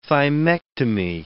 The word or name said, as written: thymectomy